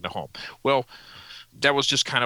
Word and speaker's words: Nahom: Now, they didn't have vowels in the ancient Hebrew, so this would have been pronounced as Nahom.